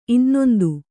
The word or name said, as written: ಇನ್ನೊಂದು